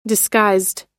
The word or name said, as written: disguised